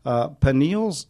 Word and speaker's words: pineal: Now, pineal's cyst, is that how you pronounce it? – Yeah.